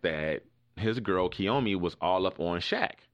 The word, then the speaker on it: Kiyomi: But in some other celebrity news, Bow Wow and his girlfriend, is her name pronounced Kiyomi? – Yeah, Kiyomi.